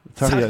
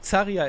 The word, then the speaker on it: Zaria: wie spricht man sie aus, bitte hilf mir. – Zaria ist die offizielle, ja. – Ich hätte schon wieder Zaria gesagt, es ist einfach wirklich wirklich. Zaria ist es.